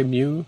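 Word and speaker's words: Mu (μ): How do you say mu? – Mu. Yep. Yeah, use the coefficient of friction.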